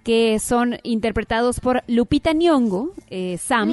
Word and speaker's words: Nyong'o: Oye, pero además es imposible pronunciarlo como si no fuera ñ, pero no es ñ, es ny. – ¡Ny! – ¡Nyong'o! ¡Nyong'o! – O sea, Nyong'o.